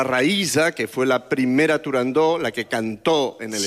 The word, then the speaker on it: Turandot: No se debe pronunciar Turandot. – No, Turandó. – La ópera se titula Turandó.